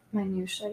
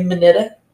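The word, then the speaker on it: minutiae: Minutia, yeah. – Minutia. What did I say? Minita? – Yeah, you went minuta? – Yeah.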